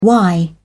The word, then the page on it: why: British English